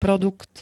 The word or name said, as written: produkt